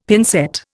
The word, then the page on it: pincette: Indian